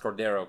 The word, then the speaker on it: Cordeiro: Is C-H-E-V-A-N. – Siobhan Cordero. C-O-R-D-E-I-R-O. – Cordero. – I know it's pronounced, the last name pronounced Cordero, because he's played behind Cole McDonald. – Yes. – And I've heard him say it.